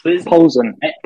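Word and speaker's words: Plzeň: Bayern, Barcelona, Inter, and Pulse. Sorry, I know I've said that wrong. – Victoria, please. – Polzen.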